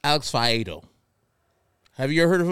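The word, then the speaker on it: Faedo: It was I think it's Alex Fiedo. – It is.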